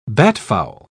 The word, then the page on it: batfowl: British English